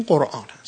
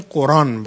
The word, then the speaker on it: قرآن: اما تلفظ دومی که اشاره کردم که کلمه قرآن باشد، اینجا یا با حذف همزه است یا با تخفیف همزه است.